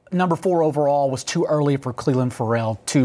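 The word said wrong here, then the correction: Ferrell: Furl. Isn't that a weird way to pronounce it, though?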